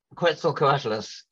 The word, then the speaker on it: Quetzalcoatlus: And the largest flying animal is a Quetzalcoatlus, or how do you pronounce it? Well, Quetzalcoatlus,